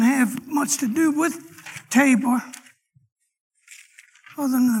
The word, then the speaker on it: Tabor: And I'm actually going to get three of them in today, but the main one is going to be Mount Tabor is how it's pronounced.